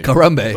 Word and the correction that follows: Harambe: It's Harambe.